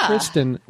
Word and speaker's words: Christine: It's spelled Christine and pronounced Kristen, which are both female ways of spelling and pronouncing.